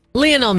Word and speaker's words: Lionel: I've been saying Lionel Messi. It's Lionel. – Well, whatever. – Lionel Messi.